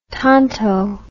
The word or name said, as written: Tonto